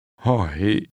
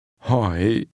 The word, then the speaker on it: Hỏi: Hỏi. Hỏi. Hỏi. – This word is pronounced with a falling, rising tone. – Hỏi.